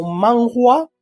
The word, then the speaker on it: manhua: En coreano la palabra se adopta como manhua, man, como suena, HWA, y en chino como manhua con HA, HWA. Manhua se pronuncia. – El H en chino es H. Es manhua.